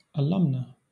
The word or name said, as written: alumna